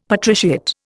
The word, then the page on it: patriciate: Indian